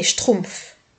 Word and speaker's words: Schtroumpfs: Ce sont les Schtroumpfs difficile à prononcer. Difficile à épeler aussi. Les Schtroumpfs, ça s'écrit S-C-H-T-R-O-U-M-P-F-S à la fin.